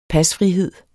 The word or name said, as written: pasfrihed